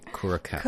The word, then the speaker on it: Curaçao: Okay, how do you say this correctly? – Curaçao. – Curaçao. I'm going to copy you then. Curaçao.